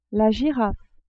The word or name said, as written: girafe